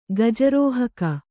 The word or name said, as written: ಗಜಾರೋಹಕ